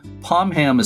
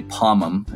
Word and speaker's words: Pomham: And let me just say, Pomham is kind of the traditional pronunciation. It's named for an Indian sachem there. But a lot of people say Pomham, and I would say that they're both probably equally correct, Pomham or Pomham.